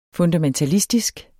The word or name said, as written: FUNDAMENTALISTISK